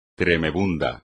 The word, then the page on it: tremebundo: espagnol